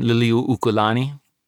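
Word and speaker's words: Liliuokalani (Liliʻuokalani): Little Queen Liliuokalani.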